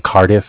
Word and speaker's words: Cardiff: I'm from Cardiff. It's spelled C-A-R-D-I-F-F, as in Cardiff, Wales, in England. – Okay, 1 second. You said Cardiff? – Yeah, spelled like C-A-R as in car, D-I-F-F as in different. – Okay, and what was your first name?